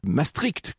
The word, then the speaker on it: Maastricht: Maastricht, comme dit d'ailleurs Jean-Marie Le Pen, il semble que ce soit la bonne prononciation au demeurant.